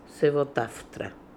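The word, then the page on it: Sevotartra: Russian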